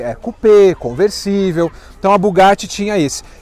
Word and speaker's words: Bugatti: E aqui do lado, pessoal, tem um Bugatti ou Bugatti, que é a pronúncia certa.